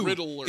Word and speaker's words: Riddler: Yeah, he's saying, he's saying three syllables. It's two. Riddler. It's Riddler.